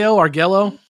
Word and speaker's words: Arguello: Arguello? How do you say that? – Argello, something like that.